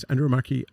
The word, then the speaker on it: Andromache: She's Andromache of Scythia.